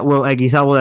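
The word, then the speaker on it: Will Eguizabal: You can also find my personal Twitter at Will Eggiesabel. That's W-I-L-L last name, E-G-U-I-Z-A-B-A-L.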